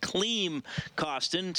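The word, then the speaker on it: Klim Kostin: Yes, it is pronounced Kleem Costin, spelled K-L-I-M.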